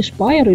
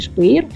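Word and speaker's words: Speyer: В 1529 году, то есть через 12 лет после того, как Мартин Лютер разослал свои 95 тезисов, был собран Рейхстаг в городе Шпайер или Шпейр, по-разному это произносится, где в очередной раз Лютера подвергли запрету.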